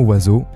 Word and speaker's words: oiseau: Voyez-vous, O-I, ça fait oie. E-A-U à la fin, ça fait O, et le S au milieu se prononce Z, oiseau. Donc finalement, on ne prononce aucune de ces lettres.